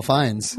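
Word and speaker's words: Fines: Yes, but mine is spelled like the word. – Yeah. – So it's F-I-N-E-S. And yeah, I was very lucky on Twitter and Instagram, everything like that.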